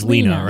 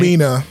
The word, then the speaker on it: Lena: I think it's Lena, right? – Lena. – Yeah.